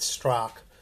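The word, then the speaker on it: Straka: I think it's spelled Strock, but it's Strock.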